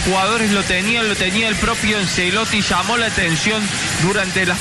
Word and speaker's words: Ancelotti: Y dice, la CEP se pronuncia como Che. Entonces, Ancelotti.